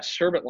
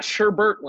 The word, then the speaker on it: Sherbet: Yeah, Sherbet Land. It's not Sherbert land.